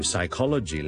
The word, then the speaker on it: psychology: 好多时候 P 音开头有啲字咧系唔需要读嘅。例如 psychology 啦，我哋都做心理学，psychology 个 psy 咧系 sy 咁嘅音就可以啦，就唔需要咧 psy，OK，psychology。